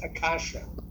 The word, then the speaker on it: acacia: It's pronounced akasha. Akasha. – Akasha. You pronounce the S. – A-C-A-C-I-A, right? – There's an S.